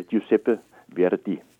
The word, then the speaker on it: Giuseppe Verdi: Altså, han hedder Giuseppe Verdi.